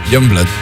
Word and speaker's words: Youngblood: Youngblood.